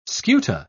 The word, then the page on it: scuta: British English